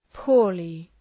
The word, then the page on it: poorly: ‘pʋərlı